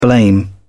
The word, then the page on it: blame: British English